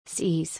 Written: Sees

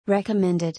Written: Recommended

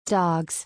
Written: Dogs

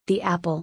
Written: The apple

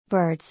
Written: Birds

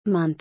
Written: Month

